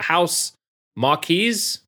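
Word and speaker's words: Housemarque: House Marquee.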